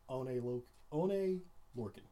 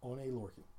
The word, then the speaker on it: Oney Lorcan: Oney Lorcan. – Oney Lorcan? – Oney Lorcan.